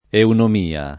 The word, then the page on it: eunomia: italiana